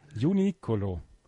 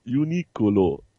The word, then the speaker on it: Uniqlo: Und weil die Japaner das R ähnlich wie eines L aussprechen, ist es Uniqlo. Du musst das eher so ein bisschen rollen.